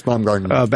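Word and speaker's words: Baumgartner: Some of the testing was downright scary in this film, because how do you say his name? Baumgarten? – Yeah, Felix Baumgarten.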